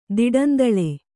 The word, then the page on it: ದಿಡಂದಳೆ: Kannada